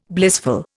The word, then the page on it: blissful: Indian